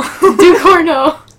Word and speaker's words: Ducournau: It's spelled Ducournau. – Can I? – it's right there. – Oh, Ducournau. – Ducournau. – You said it right.